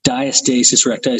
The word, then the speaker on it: diastasis recti: Before we went to break, Bree, you were talking about the that diastasis recti. Is that how you say it? – Yeah.